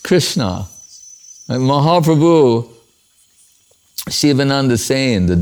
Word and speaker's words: Krishna: The Dan Sagar is in Krishna. 2 syllables. Anyone can say it.